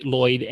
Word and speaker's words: Loyd: My name is Tony Loyd, and my last name is spelled with one L, right? L O Y D.